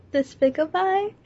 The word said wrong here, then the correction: despicable: How do you pronounce it, despicable? Or despicabye? – It's despicable. – It's despicable, oh.